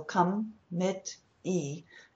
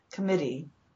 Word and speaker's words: committee: But your stressed syllable is always longest and strongest, which is also a reason why the E is not as exaggerated as it might be in some words. But mit, short vowel, but stronger emphasis, committee.